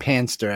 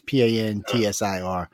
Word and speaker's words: Pantsir: I'm probably saying it wrong. It's more like Panster, actually. It's Pantsir.